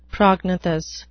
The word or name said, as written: prognathous